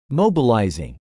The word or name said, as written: mobilizing